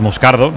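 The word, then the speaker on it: Moscardó: Como bien ha pronunciado Unai, es Moscardo, no es Moscardo, Moscardo, es brasileño, sí, sí.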